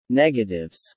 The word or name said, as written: negatives